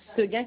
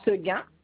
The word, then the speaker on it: Seguin: Alors, Cheguin, alors, C-H-E, oui. – Alors, on recommence. – Alors, Cheguin, Cheguin, Cheguin. – Non. Seguin Kadish. Alors, Seguin, S-E-G-U-I-N, trait d'union. – Oui. – Alors, Kadish s'écrit C-A-D-I-S-I. C-H-E. C-A-D-I-C-H-E.